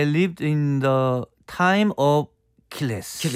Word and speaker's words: Achilles: A가 발음이 잘안 들려요. 모음이라서. 그래서 킬레스. – 킬리스. – 킬리스. 이렇게 들립니다.